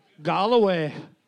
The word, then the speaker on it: Galway: Galway. – Galway. – Are you... Am I pronouncing it correctly? – Yes.